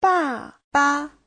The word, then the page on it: 爸爸: ba4ba5